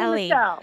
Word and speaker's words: Elie Mystal: I, uh, but at, after starting to listen to this uh, book by uh, how, how, do you pronounce his name? Ellie, Ellie or Ali? – Ellie, Elie Mystal.